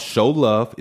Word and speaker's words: Cheaux Love: They're called Show Love. It's spelled C-H-E-A-U-X. L-O-V-E.